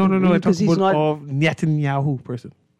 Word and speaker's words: Netanyahu: Benjamin Netanyahu. Netanyahu.